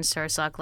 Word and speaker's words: Sirsak: Yes, Tam and like salmon, Sirsak like sir and the sock on your foot.